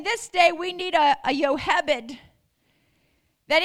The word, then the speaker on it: Jochebed: Jochebed, however you say her name, we always said Jochebed, but it's really Jochebed, was the mother of Moses who would deliver a deliverer.